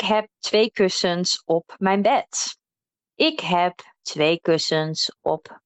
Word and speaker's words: bed: So make sure you pronounce that D as a T there. So it's not bed, maar bed.